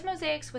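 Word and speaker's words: Mosaix: So you pronounce it like it's multiple mosaics, but it's actually one mosaic with an X.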